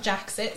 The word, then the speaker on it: Jax IT: So, first off, a shout out to Jaxit. I hope I have probably not pronounced that right, actually. It's Jax IT, isn't it? – Yeah, he's in IT, isn't he? – Yeah, so it's not Jaxit.